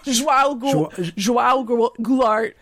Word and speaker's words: João Goulart: Well, I believe they may have played some role in the deposition of, I shudder to think how I might pronounce his name, but the left-wing president, Joa Goulart. Wrong? – João Goulart.